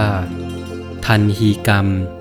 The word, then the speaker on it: ทัฬหีกรรม: สวัสดีครับพบกับรายการคำวัดวันนี้เสนอคำว่าทันฮีกรรม